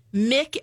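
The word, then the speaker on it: Muick: Okay, well there are two left, Mick and Sandy. Now Mick, i I believe that's how you pronounce it. It's M U I C K.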